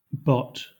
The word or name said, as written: bot